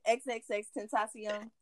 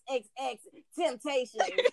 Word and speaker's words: XXXTentacion: XXXTentacion. – Oh, that's his name. – Yes, give him a little flavor and culture. You talking about XXX Temptation.